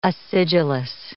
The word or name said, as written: acidulous